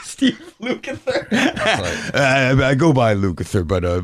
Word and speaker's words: Lukather: So, you know, he Lucifer, can't really do anything. – Right? Did I say it right? – Lucifer? – Steve Lukather. – Lukather. Of Toto.